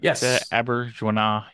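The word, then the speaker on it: Auberjonois: Is that Aberjonah? Yeah, saying that right. It's French.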